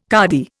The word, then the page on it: cardy: Indian